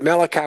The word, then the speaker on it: Malachi: There's a little bit in Malachi, but Malachi was actually the last, or Malachi as the Hebrews would pronounce it, is the last book in the Old Testament that before the 400-year separation.